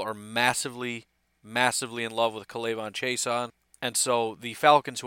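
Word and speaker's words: K'Lavon: And with the 14th overall pick in the 2020 NFL Draft, the Atlanta Falcons select Kalaivon Chaisson, edge rusher, LSU. And I've heard that offense, that first name pronounced seven different ways. That's what I'm going with, Kalaivon. I've heard Kalaivon, I've heard Clavon, whatever.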